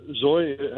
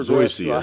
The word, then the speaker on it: Zoysia: Is Zoya? How you pronounce it? Zoysia or Zoya? Is that a better Zoysia. – Grass? Zoysia. – Yes.